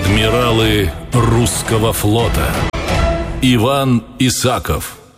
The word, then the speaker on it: Исаков: Хотя отец будущего адмирала требовал ударения на последнем слоге «Исаков».